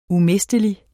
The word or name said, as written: umistelig